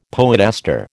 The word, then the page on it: poetaster: American English